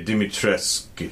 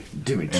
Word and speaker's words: Dimitrescu: You say Dimitrescu. Dimitrescu. And you just barely pronounce the U. That's how the, she says it in the game.